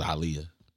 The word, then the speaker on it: Aaliyah: Or they do it Aaliyah too. It's Aaliyah.